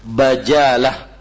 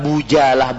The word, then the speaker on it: Bajalah: Ya, cara membacanya adalah Bajalah. Ya, dengan ba-nya ada fathahnya, bukan Bujalah, bukan Bajalah.